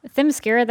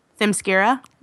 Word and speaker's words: Themyscira: How do you pronounce it? – Themyscira, that's how I pronounce it. – Themyscira? – Yeah.